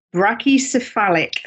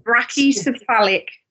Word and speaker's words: brachycephalic: So there are certain breeds of dog who suffer a lot worse in the hotter months, and these are called brachycephalic. Sam, am I saying that right? Spot on. Brachycephalic dogs.